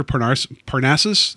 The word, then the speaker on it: Parnassus: Well, I think one of the more interesting outputs of that is that Imaginarium of Dr. Parnassus,